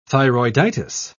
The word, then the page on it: thyroiditis: British English